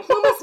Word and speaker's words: Plumas: Did you pronounce it right? – I pronounced it right. Plumas National Forest.